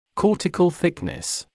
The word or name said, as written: cortical thickness